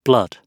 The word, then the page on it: blood: British English